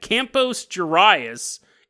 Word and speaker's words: Campos Gerais: Sorry, it's not even close to how you pronounce it. I don't even think there's any word that's pronounced like that. It's almost like I got lockjaw in the middle of saying that. Campos Gerais in Brazil.